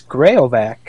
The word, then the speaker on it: Graovac: How do we pronounce the Wild's new third line center's name? – I believe it's Graovac. – Is it just straight up Graovac? – That's what I've been hearing.